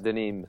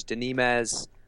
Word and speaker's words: DeNimes: I, I, I hear Serge Denimes. Serge Denims. Um, but no, it, it is Serge Denim, like Nîmes in France.